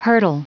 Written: hurdle

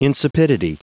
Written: insipidity

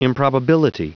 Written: improbability

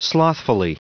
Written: slothfully

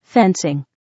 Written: fencing

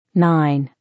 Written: 9

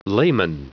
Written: layman

